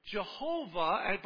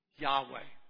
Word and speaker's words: Yahweh: And that name has been transliterated into the English language as what? Jehovah. But scholars today believe a better pronunciation of that name is what? Yahweh.